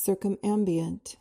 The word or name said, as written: circumambient